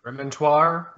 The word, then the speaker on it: remontoir: I might screw up the pronunciation of this, but it's Remontoir, yeah.